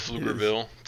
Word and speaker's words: Pflugerville: If you put the respect on it, but yes, Pflugerville. – I guess it is with a PF. Puff Pflugerville to the jokesters out there who think they're the first ones to make that joke. Yeah, Pflugerville, Texas.